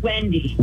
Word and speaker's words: Wendy: Rhymes with Ginny. – Did you say Judy? I'm sorry, you're going in and out a little bit. – I'm sorry, it's Wendy.